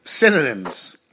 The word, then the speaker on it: synonym: How do you say that word? – Cinnamon? You mean? – No. – Cinnamon buns? – No. Like SAT. Synonyms. – What? – It's the cold that's affecting me. Synonym.